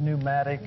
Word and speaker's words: pneumatic: Our word pneumatic, you know, the P is silent. Our word pneumatic comes from this.